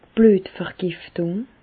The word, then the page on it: d' Blüetvergiftùng: Alsatian; Bas-Rhin; Reichshoffen